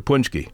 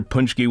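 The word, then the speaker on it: pączki: So the way I pronounce punchki is the way I learned the word on the east side of Detroit in Hamtramck,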